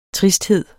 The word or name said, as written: tristhed